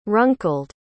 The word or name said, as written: runkled